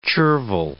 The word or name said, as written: chervil